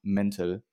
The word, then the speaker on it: mental: Die haben auch nochmal ordentlich reingeschallert, also die zwei Tage, die waren die waren nochmal, die waren äh, nochmal mental und äh, mental nicht deutsch ausgesprochen, sondern mental.